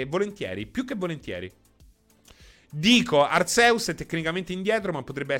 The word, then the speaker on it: Arceus: In Giappone si legge Arceus, quindi direi Arceus.